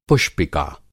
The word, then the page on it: पुष्पिका: Hindi